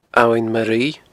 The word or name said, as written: Abhainn Ma Ruibhe